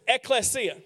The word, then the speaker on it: ekklesia: The word is ekklesia.